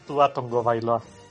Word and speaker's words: Tua Tagovailoa: Resulta que sí es Tua Tagovailoa.